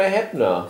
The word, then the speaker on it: Heppner: Oder Peter Heppner, wie man es aussprechen muss.